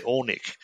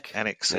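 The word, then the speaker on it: Alnwick: How did you say you pronounce it? We always say Ornick. – Anik.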